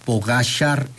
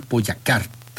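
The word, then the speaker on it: Pogačar: Dice, se pronuncia Tadej Pogacar en vez de Tadej Pogacar. Tadej Pogacar, no sé.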